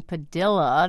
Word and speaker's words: Padilla: In the past, we've pronounced his name Padilla. That's how you've been hearing it on Morning Edition. That would be spelled P-A-D-I-L-L-A. The defendant said it that way in court. – But as he awaits trial on terrorism charges in Miami, his lawyer says his client prefers the Spanish pronunciation Padilla.